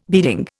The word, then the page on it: beading: Indian